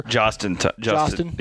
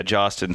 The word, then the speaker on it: Jahostin: Jahostin. Yeah, Jahostin Thomas. The H is silent.